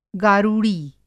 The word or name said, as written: गारुड़ी